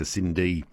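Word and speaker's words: Synd: That was Dr. Di Hughes from Deakin Uni introducing us there to Cindy, and it's spelled S-Y-N-D, a new synthetic cadaver which will greatly help local medical students who are studying at Deakin University.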